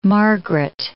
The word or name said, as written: Margaret